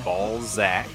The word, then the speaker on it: Balzac: That's the pronunciation of the band's name is Balzac, B-A-L-Z-A-C. – With a Z. – Yeah, Z. – Balzac.